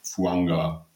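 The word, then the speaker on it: Fuaga: Und um die 14 jetzt abzuschließen, die Saints haben sich Talise Fuanga, hier habe ich mich tatsächlich nochmal über die Pronunciation unterhalten, der heißt anscheinend Fuanga, weil man das hawaiianisch so ausspricht, von Oregon State geholt.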